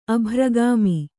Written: ಅಭ್ರಗಾಮಿ

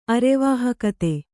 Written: ಅರೆವಾಹಕತೆ

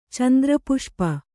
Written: ಚಂದ್ರಪುಷ್ಪ

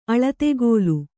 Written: ಅಳತೆಗೋಲು

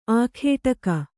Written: ಆಗಡಿಗ

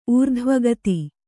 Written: ಊರ್ಧ್ವಗತಿ

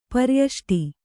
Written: ಪರಿಯಷ್ಟಿ

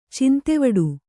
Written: ಚಿಂತೆವಿಡಿ

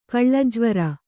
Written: ಕಳ್ಳಜ್ವರ

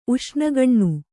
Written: ಉಷ್ಣಗಣ್ಣು

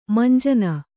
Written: ಮುಂಜಾನೆ

